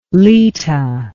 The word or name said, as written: Leta